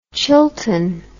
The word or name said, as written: chilton